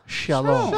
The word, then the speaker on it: shalom: But to pronounce it real is a very soft sha. – Shalom. – Shalom.